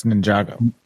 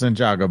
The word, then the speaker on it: Ninjago: That's Ninjago. – Yeah. – Is it? – Yes. – Ninjago. – Ninjago. – It's pronounced Ninjago, but yes, it's just the words Ninjago.